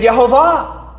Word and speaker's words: Yehovah: If you combine the three, it's Yehovah with the correct pronunciation.